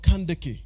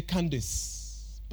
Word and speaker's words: Kandake: she was called Kandeke. The English would say Candace, but let's pronounce it the African way.